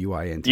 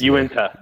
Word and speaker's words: Uinta: So to see that you are tied to that and Uinta, I don't know how to pronounce that brewery's name, the UINTA. – Uinta.